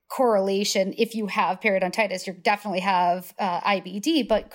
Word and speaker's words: periodontitis: actually have higher risk of periodontitis.